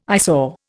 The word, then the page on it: eyesore: Indian